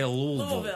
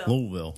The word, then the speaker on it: Louisville: That's not how they say it. They say Louisville. – Louisville.